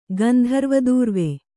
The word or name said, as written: ಗಂಧರ್ವದೂರ್ವೆ